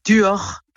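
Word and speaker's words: Duhort: Alors on prononce Duor. – Ah, vous dites pas le T ? – Non.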